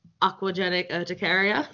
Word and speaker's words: aquagenic urticaria: So can you tell us about aquagenic urticaria? I'm saying that correctly. – Yeah, you got it.